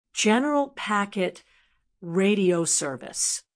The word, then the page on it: general packet radio service: American English